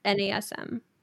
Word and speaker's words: NASM: Is it NASM or NASM? – Yeah, no, you're saying it right. NASM.